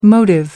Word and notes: motive: American English

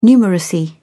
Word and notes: numeracy: British English

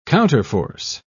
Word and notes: counterforce: British English

